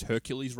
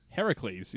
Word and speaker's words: Heracles: I love that they are pushing that the fact that everyone says Hercules wrong, apparently. – Heracles is how it's pronounced.